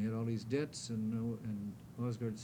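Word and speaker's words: Aasgaard: That's, Osgard is spelled A-S-G-A-A-R-D, and his initials were J.A.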